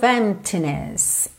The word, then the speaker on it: emptiness: Então, Spend your days full of emptiness. Emptiness. A gente não pronuncia a letra P ok? Então não se preocupe em dizer emptiness, emptiness.